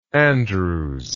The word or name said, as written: Andrewes